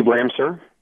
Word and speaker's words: Ramseur: Steve Ramser.